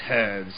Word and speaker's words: herb: Which is funny, because in the British dialect, I believe you do pronounce the H. In herb.